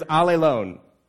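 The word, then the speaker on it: allelone (ἀλλήλων): But it's interesting because it's almost ironic because the word is pronounced all alone. Yeah, that's the Greek word. The Greek word is allelone. And it sounds like you're all alone, but when you want another, you're not all alone.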